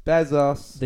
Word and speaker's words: Bezos: Bezos.